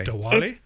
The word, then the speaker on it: Diwali: How do you say that word? – I don't know how to say it. D-I-W-A-L-I. – Oh, okay. – Diwali? – Diwali, yeah.